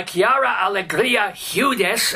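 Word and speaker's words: Quiara Alegría Hudes: This is the third in a trilogy by Chiara Alegria Hudes.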